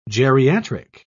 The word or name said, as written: geriatric